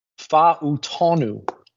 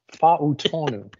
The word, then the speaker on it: Fa'utonu: Troy Fa'utonu. Fa'utonu. It's the Samoan Polynesian guys. It's usually pronounced as it is spelled, which is helpful.